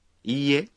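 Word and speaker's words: Ie (いいえ): Ie. – Significa no. Observen que la I de IE se pronuncia prolongada.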